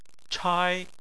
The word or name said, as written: chai1